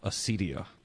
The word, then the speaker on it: acedia: Acedia. That's the official pronunciation from the Google. Acedia.